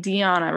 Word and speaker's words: dhyana: dhyana right that's how you say it that is, mm-hmm.